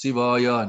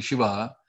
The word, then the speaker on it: Shiva: If you hear properly, the male voice, XPB, would have proper pronunciation of the Shiva, but the chorus would have sung it as Siva.